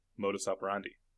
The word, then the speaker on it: modus operandi: A sharp and harsh military response to a random one-off terrorist attack seems like it might fit his general modus operandi. – See, but that's how you pronounce that, right? – Oh, yes.